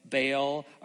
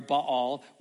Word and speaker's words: Baal: and pull down the altar of Baal or Baal, however you pronounce it, both are sometimes correct. So we'll use Baal this morning, that your father has.